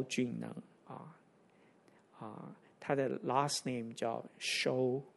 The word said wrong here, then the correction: Seow: 应该念 Siu，你知道那个潮州人啊，萧怎么念？Siu，S-E-O-W，他的 last name 怎么念？Siu 对吗？